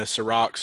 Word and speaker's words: Serac: Well, the one path to failure to me is that um, what's his name, Serac? Is that how you say it? – Yeah, Serac, Serac, and then Serac, Caleb.